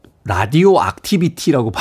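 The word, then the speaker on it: Radio-Activity: 독일 사람들도 라디오 액티비티라고 발음하는군요.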